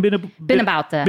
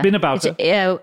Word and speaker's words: Beenaboutta: Beenaboutta. – Beenaboutta.